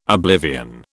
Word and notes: oblivion: British English

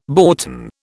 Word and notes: boughten: British English

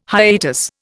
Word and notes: hiatus: Indian

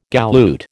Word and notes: galoot: American English

